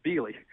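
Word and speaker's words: Biehle: Now, you mentioned Beale as well, B-I-E-H-L-E, a little town there in southeast Missouri. – And I'm sure some people wouldn't pronounce it that way, but we grew up, it's Beale.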